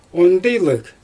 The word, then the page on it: ondijlyg: Xyzyl